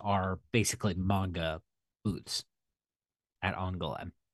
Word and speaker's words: Angoulême: In Angoulême. – Angoulême? Okay, that's how you pronounce it.